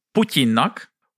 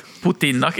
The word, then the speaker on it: Putin: Biden szólt Putyinnak, hogy jó lenne, hogyha Putinnak.